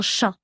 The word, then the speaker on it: chas: Mais alors sache que le trou de l'aiguille, on dit que c'est un chat. Alors c'est pas comme l'animal, ça s'écrit C-H-A-S.